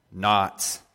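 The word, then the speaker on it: knot: Not. Yeah, the K is silent.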